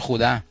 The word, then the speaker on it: Houdin: y Houdini por Houdin, que era su ídolo de aquel entonces. – Se pronuncia Robert Houdin, ¿no? – En francés sería Hubert Houdin, sí. Ah. Pero se escribe Houdin.